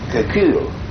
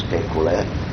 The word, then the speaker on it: Kekulé: In order to maintain the pronunciation, he put an accent aigu on the second E. Because the French would pronounce it Kekul. So now with an accent, it was pronounced Kekulé, as always.